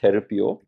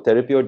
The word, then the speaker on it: Therapeuo: But it's called Therapio.